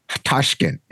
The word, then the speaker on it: Tashkent: Tashkent.